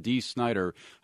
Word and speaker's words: Dee Snider: Tomorrow on the program, we talk with, get this, I'm saying it right, Twisted Sister frontman Dee Snider about his rocker past and his new Broadway album.